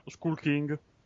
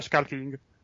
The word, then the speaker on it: Skull King: E si chiama Skull King, ho detto l'italiana Skull King, ma la pronuncia è Skull King.